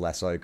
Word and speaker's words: Lasso: can you please pronounce Lasso correctly? So it's Ted Lasso, right? As in... To catch a cow? – Ted Lasso, yeah.